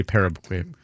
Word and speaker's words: Paraguay: How do you say Paraguay? – Paraguay.